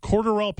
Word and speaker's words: Cordarrelle: I would love to see the conversation where it's him and Cordarrelle Patterson doing 40-yard dashes, and he's just getting smoked on. And I'm like, this is very good. – Thank you for saying Cordarrelle correctly.